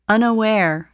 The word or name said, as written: unaware